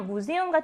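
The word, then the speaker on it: Museum: 무세움 같은 경우는 절대로 독일어의 복모음 에우가 쓰인 게 아니라 각각의 모음이어서 무세움의 에 길고요. 우는 짧은 모음 발음 내는 거예요. 이것도 잘 기억해 주셔야 돼요. 이다 무세움은 이음절 단어가 아니라 세 개의 모음으로 되어 있는 삼음절 어입니다.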